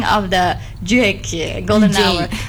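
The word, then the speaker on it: JVKE: Because before that we pronounce his name as Juke. Actually, it is Jake ya teman Pro 2. Ya, not Juke ya.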